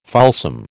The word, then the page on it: fulsome: American English